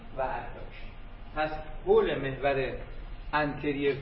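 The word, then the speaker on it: anterior: و اینا رو اگه بگه انتریور و پوستریور کاملا غلط، تلفظ انتریر و پوستریر، قدامی و خلفی.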